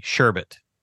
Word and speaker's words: sherbet: Sherbet, pronounced S-H-E-R-B-U-T, sherbet is how you say sherbet. – It. – Okay, well. – Is the usual word for frozen sweet dessert made from fruit or fruit juices. Sherbet, with an additional R in the second syllable, and is pronounced sherbet, is commonly less used.